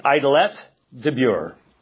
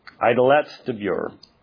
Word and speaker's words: Idelette de Bure: In 1540, he marries Idalette de Bure. Idalette de Bure. – How do you spell that? – Which one? – Both. – Idelette de Bure. D-E, capital B-U-R-E. Idalette de Bure.